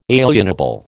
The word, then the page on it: alienable: American English